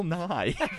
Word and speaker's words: Nighy: It's pronounced Bill Nye.